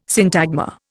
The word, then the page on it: syntagma: Indian